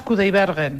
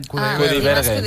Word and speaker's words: Kudaibergen: Por cierto, se pronuncia Dimas Kudaibergen. – Kudaibergen.